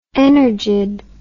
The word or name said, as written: energid